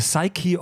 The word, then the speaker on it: Psyche: Beziehungsweise ich habe vor kurzem gelesen, dass es ja Psyche heißt. Wir haben das immer falsch ausgesprochen. The Psyche of Darth Vader. Ohne Scheiß. – Ah. Machen alle Deutschen falsch.